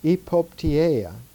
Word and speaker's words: epopteia: The third phase is the so-called epopteia, E-P-O-P-T-E-I-A, with the emphasis on the I, so you have three vowels at the end. Epopteia.